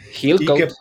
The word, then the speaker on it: Hillcoat: Es una película del 2009 dirigida por John, no sé cómo se pronuncia, Hillcoat. – Hillcoat.